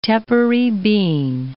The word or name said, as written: tepary bean